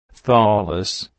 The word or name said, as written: Skied